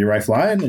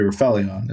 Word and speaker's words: Rifelion: I was like, so should it be Rife Lion? And she said, no, it should be Rifilion.